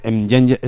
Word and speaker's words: mnjenje: Supongo que se dice Mjenje, se escribe Mnjenje. O sea, es una cosa que empieza con Mnj. Yo, ¿qué quieres que te diga? Difícilmente en castellano no se puede pronunciar. Sería como Mejunje. Pues no, Mjenje.